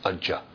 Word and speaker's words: Aja: He's Aja, unborn. A-J-A. That's how you spell it. Aja. – That's good. – Ja means? – Ja. – Born. – Birth. – A-J-A. A-J-A means not.